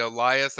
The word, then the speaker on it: Elias: I think the one thing, Elias, which that's how you pronounce his name. I know it looks like Elise, but it's actually Elias.